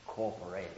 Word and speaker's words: corporeity: That's corporeity. It's a Latin word. Corporeity. Five syllables.